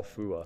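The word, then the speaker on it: Phua: All right, so we spoke about Paul Fui, and we spoke about... – That's not how you pronounce his name. – How you pronounce... Sorry, Paul. – Paul Fua. All right.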